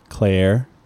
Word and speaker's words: Cleyer: It's spelled C-L-E-Y-E-R. – Clayer.